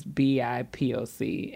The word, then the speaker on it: BIPOC: So BIPIC. Is that what you said? BIPIC. That's how you say it. – I don't think that I think it's an acronym. – So you're supposed to just really just sit here every time and say BIPOC.